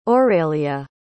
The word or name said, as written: aurélie